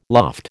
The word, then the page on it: loft: American English